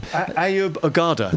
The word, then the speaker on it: Ayub Ogada: Ayub Ogada.